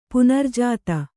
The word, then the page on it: ಪುನರ್ಜಾತ: punarjāta